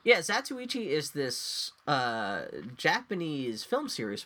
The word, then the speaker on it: Zatoichi: Criterion's, I'm going to say this wrong, Zatoichi? – That's pretty close. – Zatoichi.